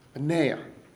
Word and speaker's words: Benaiah: Benaiah, right? Benaiah. Very good.